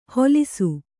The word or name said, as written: ಹೊಲಿಸು